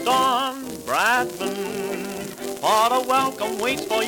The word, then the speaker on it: Bradman: Don Bradman.